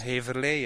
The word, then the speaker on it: Heverlee: It's a wonderful sunny blue sky day and we are in Heverlee, is that how you say it? – Heverlee, yes.